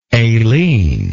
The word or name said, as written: aileen